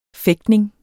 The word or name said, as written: fægtning